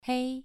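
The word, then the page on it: hei: tone 2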